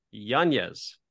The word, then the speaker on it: Yanez: I'm happy to introduce our very special guest, author Jonathan Yanez.